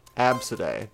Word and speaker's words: ABCDE: Absidae. The spelling is ABCDE.